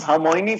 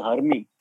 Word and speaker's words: Hermione: She used to pronounce Hermione from Harry Potter as Hermie.